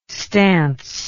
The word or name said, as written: stance